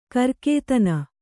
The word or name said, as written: ಕರ್ಕೇತನ